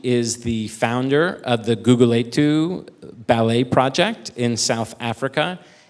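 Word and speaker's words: Gugulethu: Good pronunciation, Guguletu.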